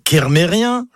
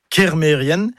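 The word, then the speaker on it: Kermerien: Et puis, il y a un autre mot, par contre, qui lui n'est jamais bien prononcé, c'est Mérienne. Mérienne, comme ça, ça ne vous parle pas. Mais si je dis Mérien, oui, vous allez reconnaître le nom de famille, Kermérien, le nom de lieu. Eh ben non, tout ça, c'est faux. C'est Mérienne, Kermérienne.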